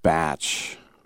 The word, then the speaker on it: Bach: Spelled Bach. And I was told that it's pronounced Batch.